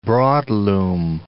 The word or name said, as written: broadloom